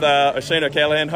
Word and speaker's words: Oisin O'Callaghan: All right, here with Oisin O'Callaghan.